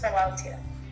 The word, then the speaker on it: philautia: The type of love I have selected is philautia. – Philautia. Philautia. So again, that's spelled P-H-I-L-A-U-T-I-A.